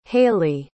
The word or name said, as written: hayleigh